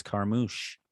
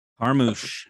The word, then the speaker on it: Carmouche: The correct answer is Liz Carmouche. – How many points did you wager, Eric? – Oh, I wagered 86 points. – Jeez. – Carmouche? – Carmouche. – Is that French? – Rhymes with douche.